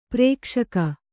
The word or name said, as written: ಪ್ರಕಾಶಕ